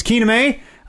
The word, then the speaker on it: Kenime: K-E-N-I-M-E. You gotta spell it, because it looks like it should be Kename. It's Kename.